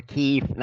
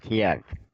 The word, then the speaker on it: Kyiv: I mean, you call the capital Kyiv, not Kiev.